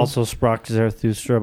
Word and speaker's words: Also sprach Zarathustra: Also Sprach Zarathustra by Strauss.